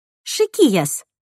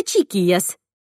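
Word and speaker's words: chiquillas: Еще они произносят букву «ч» как «ша». «Шикияс» вместо «чикияс» — девчонки.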